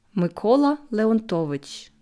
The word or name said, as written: Mykola Leontowytsch